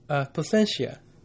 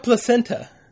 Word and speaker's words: Placentia: Oh, and only people who live in the area know Placentia. – Yeah, it's like a tiny. – But it's not Placenta. – It's not. It's spelled Placenta. So that's how you know you're local. Oh, city. Oh, Placenta. Oh, you're not a local.